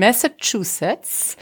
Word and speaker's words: Massachusetts: Sie ist ähm, im Dezember 1830 geboren in Amherst in Massachusetts.